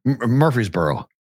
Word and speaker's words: Murfreesboro: It's Murfreesboro, okay. Tennessee, too. Same pronunciation, same town name.